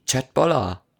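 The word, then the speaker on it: kjøttboller: Auf was denn für eine Sprache? – Auf Norwegisch. – Chatboller. – Siehste? Genau.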